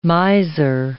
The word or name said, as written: miser